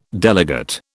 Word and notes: delegate: British English

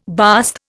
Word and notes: bast: Indian

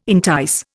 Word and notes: entice: Indian

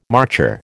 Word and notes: marcher: American English

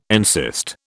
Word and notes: encyst: British English

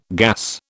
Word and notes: gas: British English

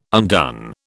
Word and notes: undone: British English

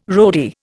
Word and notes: roadie: Indian

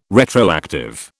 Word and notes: retroactive: British English

